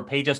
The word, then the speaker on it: Peja: He's the son of I don't know if it's Peja or Peja. – I'll pronounce it for you because I remember Peja Stojakovic.